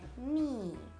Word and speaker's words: knee: in inglese si dice knee. È una parola che comincia con KN. Questa unione di due lettere, cioè K e N, mi raccomando, tutte le volte che lo trovate, ricordatevi di non pronunciare la K. Dimenticatevene, fate finta che non ci sia. Quindi ginocchio si dirà knee, inginocchiarsi si dirà kneel, kneel.